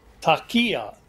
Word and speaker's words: taqiyya: And Amir, maybe you can help me with the pronunciation, but within Islam, even though the people are generally encouraged to tell the truth, there's a practice of what's called taqiyya. Is that right? – Yeah, you said it very well.